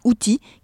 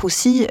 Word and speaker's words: Houthis: Elle m'a expliqué les motivations des Houthis qui se prononcent en réalité avec un S en arabe. Les Houssis euh,